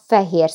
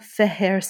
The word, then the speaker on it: Fehér: Pannonia Feher Satin. – Pannonia Feher Satin.